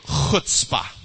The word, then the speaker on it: chutzpah: Chutzpah. There's no enthusiasm there. Come on, say chutzpah with some chutzpah. Come on, chutzpah. Okay, if you didn't spit on somebody near you, you didn't say it right, okay?